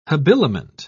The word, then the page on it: habiliment: British English